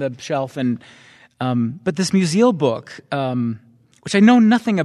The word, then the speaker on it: Musil: Musil, okay.